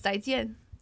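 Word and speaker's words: zai jian (再见): It's basically see you later, but it's zai jian.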